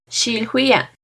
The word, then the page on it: xilhuia: Náhuatl